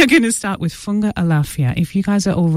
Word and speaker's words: Funga Alafia: They're starting off with a song called Funga Alafia. Did I say that right? Anne, is that correct? Funga Alafia, there you go.